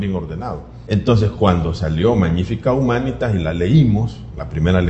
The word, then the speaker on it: Magnifica Humanitas: Entonces el Papa León 14 publica Magnifica Humanitas, la pronunciación debería ser así porque el latín no suele editar la sílaba tónica, es la segunda, Magnífica Humanitas. Entonces, nosotros estábamos esperando a ver el primer documento que publica un papa, es el documento programático.